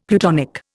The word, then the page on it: plutonic: Indian